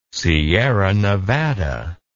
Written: Sierra Nevada

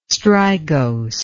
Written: strigose

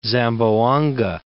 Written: Zamboanga

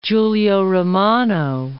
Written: Giulio Romano

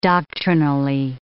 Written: doctrinally

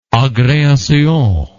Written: agreation

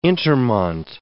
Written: intermont

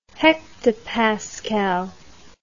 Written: hectopascal

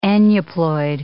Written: aneuploid

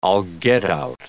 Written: all get-out

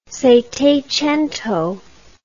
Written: settecento

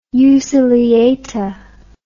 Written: euciliata

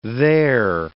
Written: they're

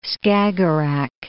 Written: Skagerrak

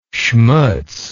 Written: schmutz

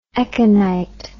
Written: echinite

